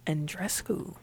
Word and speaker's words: Andreescu: Is that Andreescu? – Andreescu. – Is that how you say it? – I don't look right. – Told you I don't. – Look right. – Can't pronounce it. – Sounds about right.